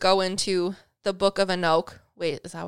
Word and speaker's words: Enoch: So anyways, egregores specifically come from the Book of Enoch, or Enoch. – I think it's Enoch. – Enoch. Okay. That would make sense.